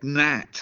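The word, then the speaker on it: gnat: The insect, Nat. – Oh, Nat. C. Oh, it's a silent G.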